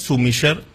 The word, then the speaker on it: sommelier: Sommelier en francés, sommelier. – Sí, porque en francés la R final no se pronuncia y se acentúa la R.